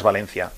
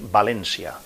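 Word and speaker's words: València: a partir de ahora no, digas Valencia, hay que pronunciarlo bien, hay que decir Valencia.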